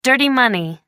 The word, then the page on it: dirty money: American English